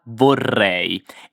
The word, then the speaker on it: vorrei: Pronunciamo insieme la parola vorrei e fai attenzione alla doppia R. Vorrei. Vorrei.